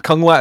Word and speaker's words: Kung Lao: Lao Kang? Kung Lao. There we go.